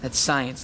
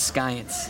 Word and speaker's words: science: Kel, that's science, not skyence. The sea is silent.